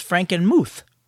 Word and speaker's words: Frankenmuth: See, Glenn wrote in last time about a great American city to visit during Christmas, which I called Frankenmuth. Well, Glenn left a comment saying, it's actually pronounced Frankenmuth, rhymes with tooth.